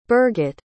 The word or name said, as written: birget